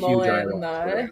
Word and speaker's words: ayahuasca: Does a lot of ayahuasca tea drinking. – Is that how you say that? I had never seen that word before, so I had no idea how it's pronounced. – Yeah, okay. Ayahuasca. But yeah, like if you're like a stoner hippie, like you drink ayahuasca in the forest and like run around the leaves.